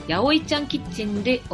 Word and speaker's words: 801: 場所は御園橋 801 広場と書いてやおいと読みます。